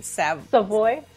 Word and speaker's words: Savoy: So it's Nicole, say your last name, Savoy.